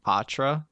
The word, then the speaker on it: Poitras: He pronounces his name Patra. – Patra. – Patras. – Patras. – Really? – Yeah. He says pot. He said pot. So the going thing is potheads. Like, if you're a fan of him, you're a pothead. – Yeah. – He doesn't have the French pronunciation.